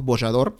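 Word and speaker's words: Bojador: Y ya para la década de 1420, eh, también los portugueses alcanzan el cabo Bollador, ¿no? Eh, con J, bueno, es la pronunciación, entendemos, Bollador, que es el nuevo, digamos, límite, entre comillas, del mundo conocido, ¿no?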